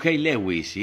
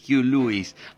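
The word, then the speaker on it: Huey Lewis: Con Hugh Lewis, que en los 80 le decimos Hey Lewis, y es Hugh Lewis. And the News es la pronunciación correcta.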